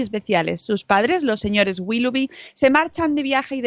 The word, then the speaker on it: Willoughby: Los hermanos Willy Woody, Will Woody, Will Willow, Willoughby. – Willoughby. – Willoughby. Los hermanos Willoughby.